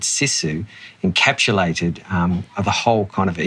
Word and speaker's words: Sisu: Well, I'll just start, Andy, by your pronunciation. It's Sisu. So Sisu is it's a Finnish word, actually.